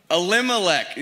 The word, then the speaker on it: Elimelech: Elimelech.